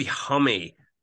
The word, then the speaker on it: Homme: It's supposed to be Homme. That's apparently how you're meant to pronounce it. That's how he pronounced it.